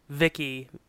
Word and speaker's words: Vicky: The character's name is Ted Lawson, and he creates Vicky, a voice input child identicant, pronounced Vicky. It's spelled V-I-C-I, and it's spelled V-I-C-C-K-Y.